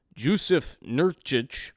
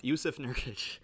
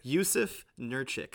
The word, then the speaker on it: Jusuf Nurkić: Jusuf Nurkić. Oh, wow, I butchered it too. Jusuf Nurkić, I believe.